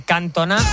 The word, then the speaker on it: Cantona: Mira, nos decía un oyente, Cantona, Oyinola no tenían acento y se acentuaban en la última sílaba. Los acentos del francés no funcionan como los del español.